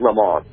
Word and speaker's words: Lemons: Not Le Mans like the race, but L-E-M-O-N-S, but they pronounce it Le Mans.